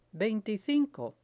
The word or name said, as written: veinticinco